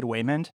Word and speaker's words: Waymond: He played Waymond. I didn't say that wrong. It's actually Waymond with a W.